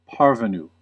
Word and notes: parvenu: American English

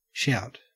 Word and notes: shout: Australian English

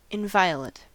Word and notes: inviolate: American English

